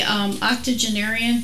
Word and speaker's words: octogenarian: And the octogenarian, did I say that right? – Yes.